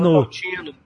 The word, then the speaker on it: Montalcino: Ó, se você for na Toscana, Montalcino, Montaltino, aí se escreve Montalcino. – Montalcino, Brunello de Montalcino.